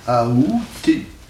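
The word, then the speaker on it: aoûter: La prononciation qui est considérée comme correcte, c'est la prononciation avec A. Aouter.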